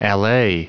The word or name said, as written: allay